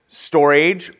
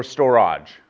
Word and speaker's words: storge: And then there's one, it's spelled S-T-O-R-G-E with the little squiggly line on top of it. It's pronounced storage or storage.